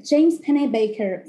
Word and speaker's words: Pennebaker: Preciso investigar a pronúncia do nome dele. James Pennebaker. Penny tipo Penny da massa e Baker tipo padeiro.